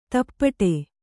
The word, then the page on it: ದುಪಟಿ: Kannada